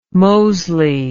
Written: moseley

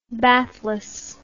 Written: bathless

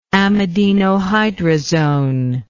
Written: amidinohydrazone

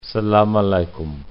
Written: salaam alaikum

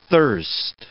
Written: thirst

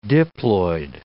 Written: diploid

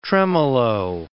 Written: tremolo

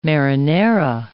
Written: marinara

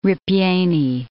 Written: ripieni

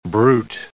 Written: brute

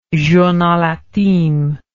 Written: journal intime

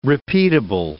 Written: repeatable

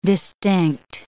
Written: distinct

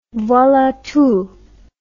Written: voila tout

